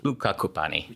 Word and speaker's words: Luca Cupani: How would you say it? – I would say Luca Cupani. – Luca Cupani.